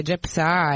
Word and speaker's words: Gepsa: Sì, prima un paio di messaggi, così, vabbè, il primo che ci chiede lo spelling di questo mostro carcerario che è Gepsa, esattamente come lo pronunciamo, quindi G di Genova, E di Empoli, P di Palermo, S di Savona, A di Ancona.